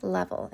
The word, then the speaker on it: Leavell: And the way that you find me there is you search my name, Stephanie Leavell. And my last name is spelled L-E-A-V-E-L-L.